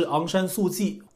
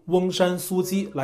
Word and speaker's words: 翁山苏姬: 在东南亚的二月一号还发生了一件大事，也是比较受人关注的，是有关缅甸的领导人翁山苏姬，大陆翻译是昂山素季。我比对了一下原本的发音啊，我们就用翁山苏姬来称呼他。当地时间的二月一号清晨，缅甸的实际领导人翁山苏姬，还有缅甸总统温敏等一些执政党高官，遭到缅甸军方突袭，在枪口下全部被带走扣押，国家权力由军方接管。